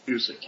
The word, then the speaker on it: Busiek: On, and I hope I said that right, Name Police? – Busiek. – Excellent. – Busiek. Yes.